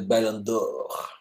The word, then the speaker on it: Ballon d'Or: Well, should I try to pronounce it right, like my boy Sebastian at Campfire Football, the Ballon d'Or.